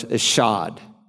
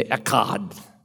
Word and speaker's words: echad: Interestingly, when the Bible says God is one, as in the Shema, like we just read in Deuteronomy 6:1, the Hebrew word for one is, we pronounce in English, eshad. They'd say echad, echad, which most literally is used to speak of a compound unity.